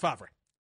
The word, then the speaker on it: Favre: Brett Favre? – Favre, yes. Pronounced Favre.